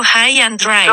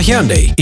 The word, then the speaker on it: Hyundai: Reproduciendo High and Dry. – No let me No te confundas, se pronuncia Hyundai y lo encuentras en tu distribuidor autorizado.